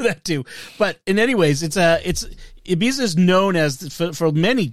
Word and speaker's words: Ibiza: I looked up how to pronounce it, and there are several different ways. The way they do it in the tape or in the recording is Ibiza.